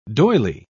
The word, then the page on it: doyley: British English